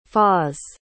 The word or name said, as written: fars